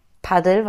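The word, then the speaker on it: Padel: Ja, und deswegen dann offensichtlich auch Paddel, weil so spricht man es auf Spanisch aus.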